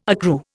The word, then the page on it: accrue: Indian